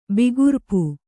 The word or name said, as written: ಬಿಗುರ್ಪು